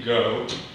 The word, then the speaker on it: Goh: And if you would, sir, I'd like to begin pronouncing your name correctly. Can you help me with that? – Certainly. Judge Michelle, my name is Timothy Goh from Alston, Newbury, for Appellant South Carolina Public Service Authority.